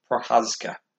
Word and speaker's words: Procházka: Like Dominic Reyes fighting Jiří Procházka. I'm not gonna lie, I've fucking struggled pronouncing that name for weeks now, but apparently it is Procházka.